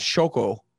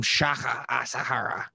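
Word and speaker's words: Shoko Asahara: You didn't say his name right. – Shoka Asahara. – It's Shoko. You called him Shaka Asahara.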